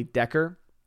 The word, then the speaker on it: Dacre: the name, which is spelled as Dacre, is probably Decker. I think is what we figured that out to actually be.